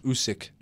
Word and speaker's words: Usyk: Usyk. – Just Usyk, that's all it is.